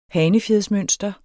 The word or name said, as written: hanefjedsmønster